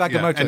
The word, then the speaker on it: Agamotto: Agamemnon. Agameto. – Yeah. I think I've said that right. – Yeah, the Eye of Agamotto.